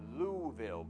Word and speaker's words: Louisville: I was corrected when I went out there. I said, Louisville's nice. Louisville, Pastor, Louisville. So they're heading out this week,